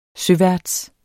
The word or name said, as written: søværts